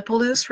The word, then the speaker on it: Palouse: Yes, the Palouse River.